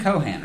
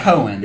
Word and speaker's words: Conan: I'm saying it right, Conan, right? – Yeah, it's not Cohen.